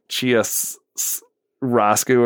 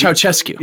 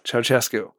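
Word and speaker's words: Ceaușescu: Dictator, sort of leader of Romania, Ceausescu, he pronounces as like... like Chia Roscu, or I don't know. – It's Ceausescu. – Yeah, Ceausescu. – Is how it's pronounced.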